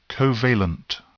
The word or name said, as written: covalent